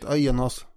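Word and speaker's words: Aeneas: Men den mycket kändare grundarmyten handlar ju om Aenas. Ska vi uttala det så? – Ja, jag kommer inte på något bättre sätt. Aenas.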